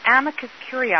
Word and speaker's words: amicus curiae: Now, ACB, there is something called an amicus curiae. Have I said that correctly, Sheila? – Yes, that's the letter that we're expecting from ACB and from the guide dog schools.